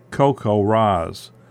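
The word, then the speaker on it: CoCoRaHS: or COCO RAHS, pronounced COCO RAS.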